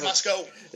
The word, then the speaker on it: Moscow: I said Moscow one time at my old job at Indiana Tech, and somebody in that office was from that area, and they just reamed the crap out of me saying, like, that is not how you pronounce it. – It's Moscow.